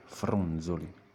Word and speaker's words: fronzoli: Prima di tutto la pronuncia, la Z di fronzoli è una Z dura o sonora? È come quella della parola zona, anche Z, oppure come in zero. Oppure zaino, non certo come nella parola azione. Si tratta pertanto della Z sonora, anche detta Z dolce, ne abbiamo già parlato.